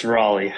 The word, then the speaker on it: Raleigh: It's Raleigh.